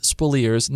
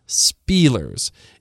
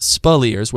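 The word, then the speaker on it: Speleers: And by the way, his name is Spilliers, not Spielers. It's pronounced Spilliers with the emphasis on the second syllable.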